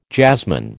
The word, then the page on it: jazzman: American English